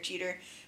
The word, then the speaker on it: Jeter: Derek, but it's Jeter, right? – It is Jeter.